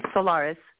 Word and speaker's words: Solares: Solares, yes.